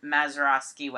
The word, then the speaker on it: Mazeroski: Outside of PNC Park on Mazeroski Way. I always pronounce his name wrong. – No, you did it correctly. – Yeah, I did it right this time.